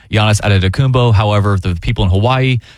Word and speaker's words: Giannis Antetokounmpo: The attention has shifted squarely to the picks after the Giannis Antetokounmpo domino fell late Monday night. Chase, how do you say that? – Giannis Antetokounmpo.